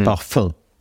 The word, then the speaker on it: parfum: Вот оно произносится в литературном немецком языке вот этим вот носовым «н» в конце, парфюм парфюм. Как по-французски было принято произносить лет 100-200 назад.